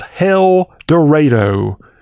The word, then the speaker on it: Hell Dorado: As Bitter Southerner announced on Twitter this week, do not miss Tara Fondry's incredible photographs in today's story, The Artistic Rebirth of Hell Dorado. I said it right. Hell, H-E-L-L, Dorado.